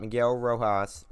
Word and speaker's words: Miguel Rojas: The club's most respected veteran shortstop, Miguel Rojas, is that how you say it? – Yep.